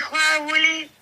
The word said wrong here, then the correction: Halloween: Feliz Halloween.